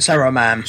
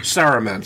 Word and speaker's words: Saruman: I said, how do you say his name? Smirnu? Or what was it? – Saruman. – Saruman, sorry. My brain was on overdrive.